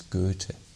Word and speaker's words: Goethe: You would but the right pronunciation is Goethe.